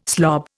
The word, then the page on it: slob: Indian